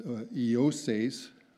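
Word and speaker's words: Joses: We read, and Eoses, that's pronounced Eoses, J-O-S-E-S. I had to listen to that online to make sure I got it right. It's not intuitive to know the pronunciation.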